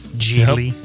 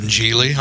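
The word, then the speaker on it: Gigli: Michael's favorite movie, Jiggly. Geely, however it's pronounced. – Geely? – Geely. Yep. – I didn't know he was in Geely.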